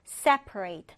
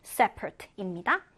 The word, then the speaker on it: separate: 이 단어는요, 여러분, 분리하다, 동사로도 쓰이고, 분리된 형용사로도 쓰이는데, 발음이 약간 다릅니다. 우리 분리하다라고 할 때는 separate 이고, 반면 분리된, 별개의, 형용사로 쓸 때는 separate입니다.